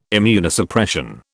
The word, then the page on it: Immunosuppression: British English